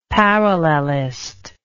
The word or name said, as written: parallelist